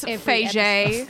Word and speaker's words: Feige: Good on Kevin Feige. – Good on. Actually, it's Feige.